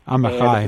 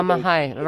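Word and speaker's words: Amichai: He's an Israeli poet mispronounce his name, but Yehuda Amachi. – Amachai. – Amazing how Amachai. Robert Pinsky knows this.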